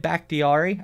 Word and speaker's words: Bakhtiari: Number three, I have Green Bay Packers, David Bakhtiari.